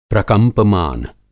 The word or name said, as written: प्रकंपमान